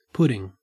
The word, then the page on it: pudding: Australian English